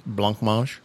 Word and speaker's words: blancmange: Blancmange.